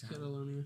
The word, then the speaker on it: Catalonia: Is it Catalonia? – Yes, it's Catalonia. – Did I say Catalona? – You had some weird spacing between the syllables.